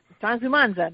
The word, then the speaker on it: Transumanza: Okay, so Transumanza, that's how you say it in Italian.